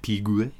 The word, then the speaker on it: Piguet: Piguet? – Piguet.